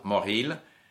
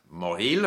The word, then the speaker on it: Mauril: M-A-U-R-I-L, Moril. By the way, words in French that end in I-L are, half the time the I-L is pronounced as il, and half the time it's not pronounced like le nombril.